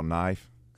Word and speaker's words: knife: He's talking about when he's younger and the teacher asked him to spell knife. He said N-I-F-E. And she said, no, Cat, that's wrong. It's K-N-I-F-E. The K is silent.